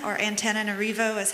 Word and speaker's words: Antananarivo: So Antananarivo is how they say it.